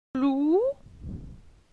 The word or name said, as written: Glou